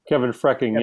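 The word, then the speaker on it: Kevin Freking: Is it Kevin Fairkeen? I'm not sure I'm pronouncing it right. – Kevin Frecking, yes.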